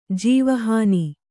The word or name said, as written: ಜೀವಹಾನಿ